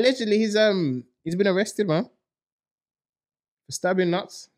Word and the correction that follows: Not3s: notes, nuts, whatever. Notes, notes. – Notes. It, it looks like it's spelled nuts, isn't it? Yeah, it's because it's with a three.